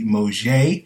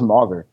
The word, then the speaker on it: Mauger: I thought it was Mauger. – No, it's it's Moje. Well, apparently it's Moje from the official uh, pronunciation guide.